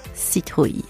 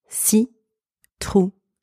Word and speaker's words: citrouille: Citrouille. So let's start with the first syllable. Si. Si. So the letters C and I in this word are pronounced si.